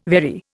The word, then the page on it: very: Indian